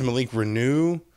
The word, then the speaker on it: Renaud: Malik, I guess it's Malik Renaud. Mm-hmm. Apparently, that's how we're pronouncing it. – I think so. – That's how it was pronounced all night.